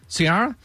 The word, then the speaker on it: Ciara: Number three, Ciara. Am I saying that right? – Ciara.